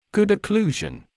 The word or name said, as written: good occlusion